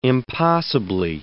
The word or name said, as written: impossibly